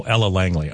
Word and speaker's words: Ella Langley: Did I say her name wrong? Oh, Ella Langley. Okay, yeah. Okay, I didn't say it wrong.